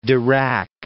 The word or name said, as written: Dirac